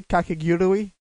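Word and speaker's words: Kakegurui: So today the whole anime is named Kakigurui. Am I pronouncing that correctly? Kakigurui. – Yeah, you got it.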